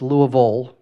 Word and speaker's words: Louisville: It's Louisville, that's the way you pronounce that. I've been there long enough now, I know how the natives say it. It's kind of funny though, isn't it? Louisville, but you don't say Nashville.